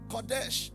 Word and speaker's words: Kodesh: The Hebrew word holy there is Kodesh.